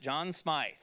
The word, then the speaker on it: John Smythe: And my professor pronounced it John Smythe, okay? So I assume he's correct.